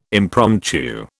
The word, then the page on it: impromptu: British English